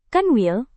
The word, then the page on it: kanwil: Indonesian